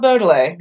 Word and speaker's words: Baudelaire: Okay, so I said Baudelaire, like, my whole life, and then Amanda goes, Baudelaire, and I'm like, okay... And then my husband this morning, when I pronounced it correctly, goes, Odele? And I'm like, no.